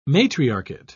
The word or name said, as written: matriarchate